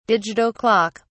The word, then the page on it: digital clock: British English